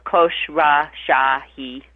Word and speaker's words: Khosrowshahi: That's very hard to pronounce, but his name is pronounced just the way it's spelled: Khosrowshahi.